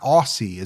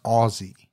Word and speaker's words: Aussie: You know, somebody corrected me. It's not Aussie. It's Aussie. – Aussie. – Even though it's spelled like you would say it Aussie.